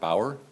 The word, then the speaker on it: Bauer: My name is Robert Bauer, B-A-U-E-R.